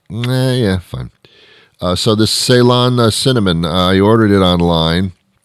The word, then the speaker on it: Ceylon: but there's a certain type of cinnamon only that has those health benefits to that extent, and that's Ceylon cinnamon, like Celine Ceylon. C-E-Y-L-O-N, I'll repeat it for the hard of hearing, C-E-Y-L-O-N, Ceylon, I think is how you pronounce it, cinnamon.